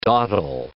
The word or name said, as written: dawdle